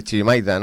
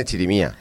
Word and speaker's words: chirimía: Has visto, David, como tampoco lo digo mal, porque yo tiendo a decir chirimaita, ¿no? Y Y en el programa de fiestas pone chirimía.